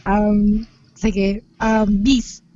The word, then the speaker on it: Bice: every time na itatry ko i-explain sa tao na BC yung pronunciation niya at hindi Vice, na nako-confuse sila kasi ang spelling ng brand na meron ako ay B-I-C-E. – B-I-C-E.